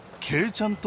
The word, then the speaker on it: 鶏ちゃん: 旅館の女将に聞くと、この辺りには鶏ちゃんと書いてケイちゃんと読むご当地グルメがあるという。